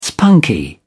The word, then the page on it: spunky: British English